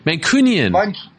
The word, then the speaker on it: Mancunian: Mancurian celebrity scene. Did I say that correctly? – Mancunian. – Mancurian. No, is that wrong? – Mancunian. – Cunian. Mancunian. – There we go. – My bad. Mancurian sounds like a manicure.